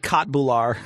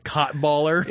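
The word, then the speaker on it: köttbullar: It's like Kotbular. – It's pronounced Kotballer.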